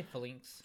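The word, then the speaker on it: Falinks: Or Phalanx. Flinks? – Yeah. – That's how you say it, right? – I'd say Flinks. – Flinks? Yeah, Flinks.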